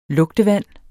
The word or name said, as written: lugtevand